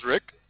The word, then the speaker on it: Rick: Well, my name is Rick, R-I-C-K, Rick.